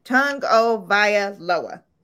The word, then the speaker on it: Tagovailoa: It's pronounced Tagovailoa. – Tagovailoa.